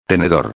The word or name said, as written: tenedor